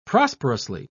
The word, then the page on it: prosperously: British English